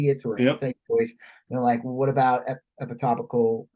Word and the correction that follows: ectopic: Right? Is that the right way to say it? I never. – e- ectopic. – Yeah. Ectopic pregnancy.